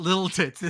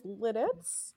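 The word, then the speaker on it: Lititz: The correct pronunciation is Littits. – Littits. – But the preferred alternate pronunciation is Littits, like you're in the Littits.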